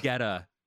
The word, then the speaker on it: Guetta: It's David Guetta.